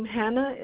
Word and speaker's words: Hannah: Brisa, it's 7, and then my nickname, Hannah, and it's spelled like Hannah Montana, H-A-N-N-A-H at the end.